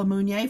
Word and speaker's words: Munier: So I want to thank Paula Munier for being my guest today.